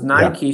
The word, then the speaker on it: Nike: Because Nike should be pronounced more like Mike, Nike.